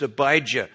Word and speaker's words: Abijah: And in 2 Chronicles, that has a J in it. It's pronounced Abijah.